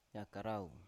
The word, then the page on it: yakarau: Biak